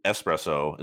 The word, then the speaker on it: espresso: Yeah, but like I'm saying, espresso is the right way to say it. Where some people will say espresso. There's no X.